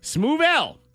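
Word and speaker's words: Smoove L: Smooth L is pronounced, or spelled, it's pronounced smooth L, spelled S-M-O-O-V-E. Apostrophe L.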